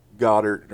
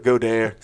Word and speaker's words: Goedert: and they could literally line up Ertz and Goddard or Goedert or whatever his name is. Why couldn't it be like Johnson, something easy? But Goedert, say it right, Goedert. Like Frenchie.